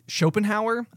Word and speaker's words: Schopenhauer: The guy has a really, Schopenhauer, I believe is how it's pronounced. – Schopenhauer, yeah.